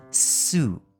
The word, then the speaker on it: soup: 来，汤各位注意哦，是 soup。也是长母音，soup，啊，那个 u 啊，它跟注音符号的 u 不一样。注音符号的 u，它的发音的位置比较在口腔的中后方，u，但是这个汤，它的发音是在靠近门牙后方比较前面的位置，所以是 u，u，soup，soup，来喝一些汤吧。